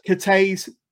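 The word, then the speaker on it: Cathays: So the Cathy's Community Centre in Cardiff. – Cathay's.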